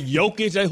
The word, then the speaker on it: Jokic: Jokic. Who has a silent J?